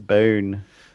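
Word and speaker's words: Bauhn: One of the ones I did says bone. Oh, ah, well, let's try this middle one here. – No. Bone. Bone.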